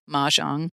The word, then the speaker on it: mahjong: okay, how do you say it? – Mahjong. mahjong. You teach me how to play mahjong.